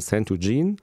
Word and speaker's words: Centogene: Ich glaube, das wird Englisch ausgesprochen, dann müsste es heißen Centogene.